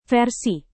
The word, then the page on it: versi: Indonesian